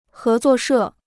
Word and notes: 合作社: hé zuò shè

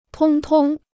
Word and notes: 通通: tōng tōng

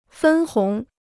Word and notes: 分红: fēn hóng

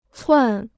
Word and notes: 窜: cuàn

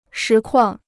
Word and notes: 实况: shí kuàng

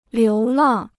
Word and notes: 流浪: liú làng

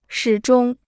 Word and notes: 始终: shǐ zhōng

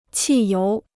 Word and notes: 汽油: qì yóu

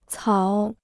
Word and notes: 草: cǎo

